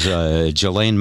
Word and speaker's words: Ghislaine: Her name is spelled Ghislaine. – Yeah. – Which is not how you pronounce it.